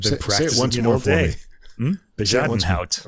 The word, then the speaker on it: Bezuidenhout: Anyway, so Rachel Podger on the violin, and the pianist here, and I looked this pronunciation up, Christian Bejadenhout. Christian Bejadenhout. That's a Dutch name, and he is born in South Africa.